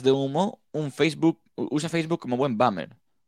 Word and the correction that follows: boomer: Boomer. boomer, Sí, es que, si no, es que se escribe boomer.